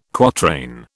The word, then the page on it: quatrain: British English